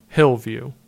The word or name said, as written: Hill View